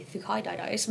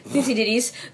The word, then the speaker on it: Thucydides: Thucydides, but I always said Thucydides, who wrote a history of Athens.